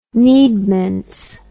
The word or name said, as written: needments